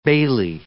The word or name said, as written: bailey